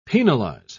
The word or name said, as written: penalize